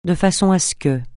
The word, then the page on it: de façon (à ce): francais